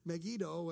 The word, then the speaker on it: Megiddo: And he said Megiddo, and one of the other pastors that was there said, no, it's the, and he called it by a different pronunciation. And so there was this discussion that was going on. And I'll never forget, our guide looked at him and says, which one of us lives here? And so from that moment on, I pronounced it the way he pronounced it.